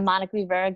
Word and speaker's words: Monica Rivera: I'm Monica Rivera.